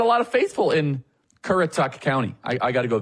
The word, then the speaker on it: Currituck: By the way, there is some, yes, I know I mispronounced the county, okay? I apologize. It is Koretic. Is that right? Oh, my goodness. No, hold on. No. It's Currituck.